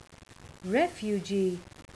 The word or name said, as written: refugee